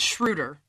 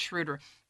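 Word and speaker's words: Schröder: Well, first of all, it's Schroeder. Put some respect on that man's name. Nobody said, many people do not say his name right. And if we're going to talk about how he's a big addition and he brings so much to the table, let's at least get his name right. It's Schroeder.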